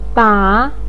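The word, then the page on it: ba3: tone 3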